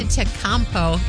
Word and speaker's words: Antetokounmpo: Antetokounmpo. That's how you say his name.